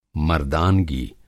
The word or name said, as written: मर्दानगी